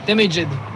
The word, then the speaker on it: Damaged: Alors racontez-nous un petit peu la création de Damaged, comment ça s'est passé, si je le prononce bien, j'espère. – Damaged. – Ouais, je savais.